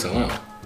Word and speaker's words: Atlanta: Yeah, if you pronounce that second T, I know the vibes. – It's Atlanta. – Yeah, smooth, just all the way through.